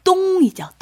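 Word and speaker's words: 똥 (东): 그래서 중국어 중에 우리 동쪽이라는 발음이 네. – 똥이죠. 똥.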